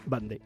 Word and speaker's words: Vendée: Se escribe Vendée, pero se pronuncia Vendée.